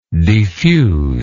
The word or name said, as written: defuser